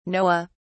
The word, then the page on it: noah: British English